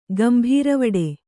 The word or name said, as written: ಗಂಭೀರವಡೆ